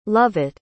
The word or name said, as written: lovett